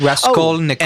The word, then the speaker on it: Raskolnikov: Raskolnikov. – Oh, and I should know. – Does that help you? – It was Raskolnikov? – Raskolnikov. – Raskolnikov. – But you could say Raskol. – Raskolnikov. – Raskolnikov.